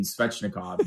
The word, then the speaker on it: Svechnikov: Or the people in the organization who add an extra syllable between the S and the V in Svechnikov, and you get Svechnikov.